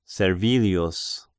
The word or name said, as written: Servilius